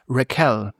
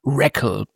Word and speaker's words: Raquel: Und ich glaube, die grooven sich da erst später so ein bisschen darauf ein, wie Mrs. Ogmonick mit Vornamen wirklich heißt, nämlich Raquel mit Betonung auf der zweiten Silbe. – Sounds like a typo. – Genau. Ich glaube, sie kriegen es nie komplett hin. Sie bleiben im Endeffekt über die gesamte Serie bei Raquel, bei Betonung auf der ersten Silbe.